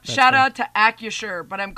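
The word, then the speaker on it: Acrisure: By the way, I've been told in my ear by Devin that it's AccuSure. It's a silent R.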